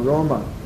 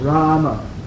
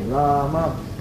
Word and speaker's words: Rama: Or Roma. – Или Рома. – Так, it's correct to pronounce Rama. – Но правильно произносить надо Рама. – Long-y, Rama. – С таким протяженным А, Рама.